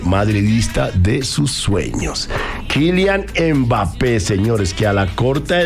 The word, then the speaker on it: Mbappé: Como es Mbappé. – Mbappé. – Mbappé, exactamente.